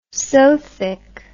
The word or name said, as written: sothic